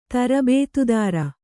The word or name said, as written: ತರಬೇತುದಾರ